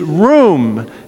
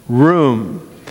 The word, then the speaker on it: ruwm: The Hebrew word that is actually used is room. It's spelled R-U-W-M. So if you play Scrabble and you've got some odd word letters, you can spell that one R-U-W-M, room.